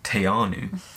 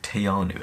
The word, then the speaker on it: Te Anau: So you'll notice in the last video, in a voiceover, I say Te Anau. And in all of the videos, it's always Te Anau. So apologies to anyone from New Zealand for the mispronunciation. It's Te Anau, apparently, I've been told.